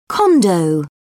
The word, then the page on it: condo: British English